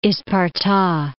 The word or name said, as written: Isparta